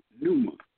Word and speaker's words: pneuma: So, in the Greek, the word spirit, pneuma, it is spelled P-N-E-U-M-A. The P is silent.